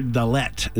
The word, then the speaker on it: Dalet: So just to give you an example, the number 4 in Hebrew is the letter Dalet, is how you say it. Obviously, it sounds like a D, and it is.